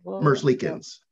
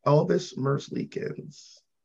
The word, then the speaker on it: Merzlikins: So Bobrovsky is now in Florida, and now you guys have Elvis, and I always butcher his name, Merzlikins. – Merzlikins, yeah. – Elvis Merzlikins.